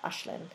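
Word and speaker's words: Aislinn: but it's A-I-S-L-I-N-N, and I believe the pronunciation is kind of like Ashlyn.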